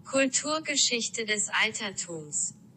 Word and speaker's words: Kulturgeschichte des Altertums: Kulturgeschichte des Altertums.